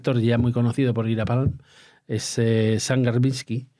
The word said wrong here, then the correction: Garbarski: a ver cómo lo se pronuncia, Garbarski.